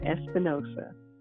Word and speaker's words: Espinoza: It's Jeanette Espinoza.